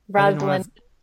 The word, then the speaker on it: Rosalinda: But oh yeah, there were some moments in here that I was like, this for is your illustrators, is it Rosalinda? – Yeah, yes, Rosalinda.